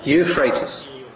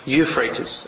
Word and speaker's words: Euphrates: I always get this name wrong. The Euphrates? Tell me, brother. What is it? Euphrates. Euphrates. Al-Furat.